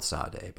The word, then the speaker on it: Sade: It's spelled Sade, but it is Sade, right? – Yeah, Sade.